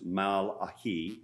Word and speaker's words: Malachi: It's actually probably pronounced Malachi, which means my messenger, Malachi. But because nobody has ever heard that word, I'll just keep calling it Malachi.